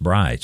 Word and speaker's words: braai: I think it's spelled like cheese, brie, B-R-I-E, but it's pronounced braai.